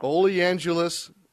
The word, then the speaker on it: oleaginous: Oleaginous. Oh, I didn't even pronounce that right, did I? – No, you got it.